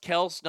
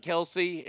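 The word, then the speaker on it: Kelce: His last name is pronounced Kels, not Kelsey.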